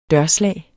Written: dørslag

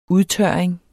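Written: udtørring

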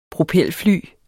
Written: propelfly

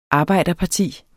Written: arbejderparti

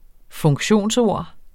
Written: funktionsord

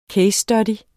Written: CASE

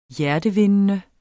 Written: hjertevindende

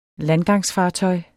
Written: landgangsfartøj

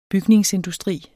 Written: bygningsindustri

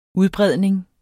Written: udbredning

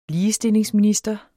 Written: ligestillingsminister

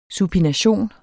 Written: supination

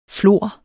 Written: FLOR

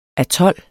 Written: atol

